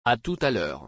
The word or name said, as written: À tout à l'heure